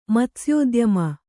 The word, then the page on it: ಮತ್ಸ್ಯೋದ್ಯಮ: Kannada